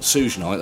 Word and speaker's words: Suge Knight: The only interesting bit of music news that I found that's not even that interesting was Suge Knight, the president of Death Row Records, getting shot in the leg at a MTV party. – From what I've heard about Suge Knight... – Suge Knight, that's it.